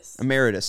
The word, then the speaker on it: Emeritus: Emeritus, right? – Is it Emeritus? – Yeah. – Is it Emeritus or am I just stupid? Emeritus. So yeah.